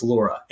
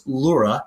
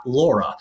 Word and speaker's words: Laura: But that is not the case with Laura. And it is pronounced Laura, not Laura. You look at the pronunciation guide, it's pronounced Laura.